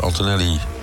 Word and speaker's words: Antonelli: Antonelli verbetert zijn tijd, zeg ik dat goed?